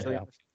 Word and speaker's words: Cillian: got Kill is it Killian Murphy? Is that how you say it? – Yeah. – Or is it Cillian? Okay, Yeah. – Cillian.